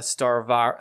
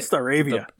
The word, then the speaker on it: Staravia: Right now we're rocking we got Starvia? Starvia, is that how you say it? – Staravia.